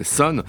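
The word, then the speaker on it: Sunn O))): ça s'écrit Sun avec deux N. Il y a un zéro qu'on prononce pas et trois parenthèses fermantes, donc c'est Sun.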